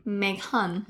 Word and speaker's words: Meghan: Like, like my name, Megan. Meghan. – Meghan. I know. Meghan. It's Meghan.